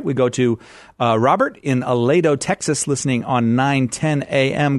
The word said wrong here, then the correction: Aledo: Starting off with the pronunciation of the city, it's Aledo, not Aledo. – Oh, I beg your pardon. Sorry. See, I'm coming up short all over the place today. Aledo, Texas.